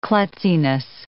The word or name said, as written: klutziness